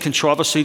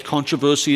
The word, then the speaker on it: controversy: Now, I've said controversy, that's the way I say it. Controversy is the way that you say it. I'll probably say it wrong over and over again. It's one of those words that after a decade in this country, I still cannot get it right.